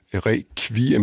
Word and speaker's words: requiem: Der siger man requiem med KV, ligesom på dansk, og der er mange andre lyde, der bliver udtalt anderledes.